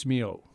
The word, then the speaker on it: Milhaud: Darius Milhaud. – Milhaud.